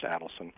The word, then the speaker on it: Adelson: By the way, that's how it's pronounced, Adelson.